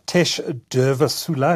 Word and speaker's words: Tesh Durvasula: Tesh Durvasula, have I said that correctly? – Yes, very good.